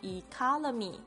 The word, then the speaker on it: economy: 然后我们在学习英文的时候，一定要特别掌握重音的位置，这样子才不会造成误会。所以如果你念成 economy，这些老外他绝对不会听懂的，就是那么奇妙。好，所以要念 economy。